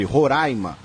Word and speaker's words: Roraima: E esclarece que a pronúncia é com A aberto sempre, Roraima.